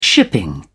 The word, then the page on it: shipping: British English